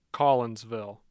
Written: collinsville

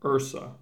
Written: Ursa

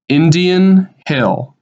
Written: Indian Hill

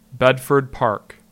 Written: Bedford Park

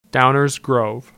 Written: Downers Grove

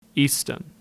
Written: Easton

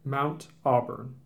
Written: Mount Auburn

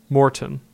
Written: Morton

